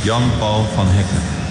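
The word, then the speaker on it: Jan Paul van Hecke: Jan-Paul van Hekken.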